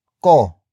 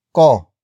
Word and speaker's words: ko: The answer is ko. Ko.